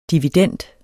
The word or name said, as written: dividend